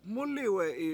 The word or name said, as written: MUUliwE7i